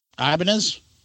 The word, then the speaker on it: Ibanez: Ibanez? – Incorrect.